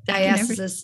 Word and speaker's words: diastasis: I think everybody, like, I think everything I've heard about diastasis, how do you pronounce it? – Diastasis. Yeah. Diastasis.